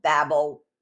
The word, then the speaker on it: Babel: The founder of Babel was a man named Nimrod, or Babel. I think the correct pronunciation is Babel.